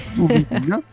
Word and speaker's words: du Vin: Allora, secondo i nuovi dati pubblicati dall'Organizzazione Internazionale della Vigna e du Vin, penso si dica così. – Se fa fe. – Va bene, già hanno detto bene, dimmi. – Oui, il fe, oui, c'est parfait, un accent parfait.